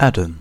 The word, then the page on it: Adam: /ˈædəm/